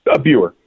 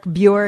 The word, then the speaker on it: Buer: And if anybody is interested, I'm speaking with Colonel Eric Beyer. Did I say it right, Colonel? – Buer. – I don't know why I can't get it right, David. I'm so sorry. David's going to have to write everything out phonetically for me. Colonel Eric Buer,